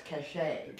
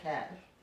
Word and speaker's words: cachet: I believe it's pronounced cachet. – Cachet, okay. – Not cash.